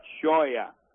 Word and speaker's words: Tchouia: And let me just kind of help you with Brandon Choya. T-C-H-O-U-I-A, then pronounced Choya.